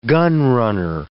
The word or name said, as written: gunrunner